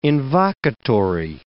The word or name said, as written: invocatory